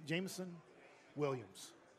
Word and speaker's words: Jameson Williams: It gives guys like Brown or Jamison Williams to do their thing. – There's a son in there somewhere. – I know. – It's in there. – Jameson Williams.